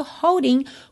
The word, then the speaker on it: holding: E o holding com apóstrofo substituindo a letra G é uma indicação é, de que esse G não vai ser pronunciado, ok?